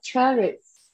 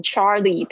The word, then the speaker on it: cherries: Maybe this bag of cher cher cherries. – 不是 cherry，是 cher cherries。会念成那个男生的名字变成 Charlie，不是 Charlie，Cherries 啊，你要注意一下 a 的声音，ch a ch Cherries，Cherries。